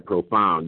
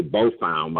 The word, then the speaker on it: profound: I just learned how to say the word right, profound. You know what I'm saying? I used to say bofound. My people check me and say, look, nobody did because they're scared of you. So I'm going to say it right, profound.